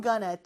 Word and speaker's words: gonna: I'm gonna take a shower. 자, I'm gonna는 I'm going to인데요. going to를 빠르게 발음하다 보니까 going to, going to, going to, going to, going to, going to, gonna가 된 거예요.